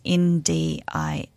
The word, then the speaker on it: Ndiaye: It's called The Witch by Maria Ndiaye, I think is how you pronounce her last name. It's Ndiaye. She's French.